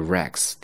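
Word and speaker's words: wrecks: 第二个也读作 Rex，第二个它是一个动词，破坏的意思。 W 是不发音的，Rex wet rocks。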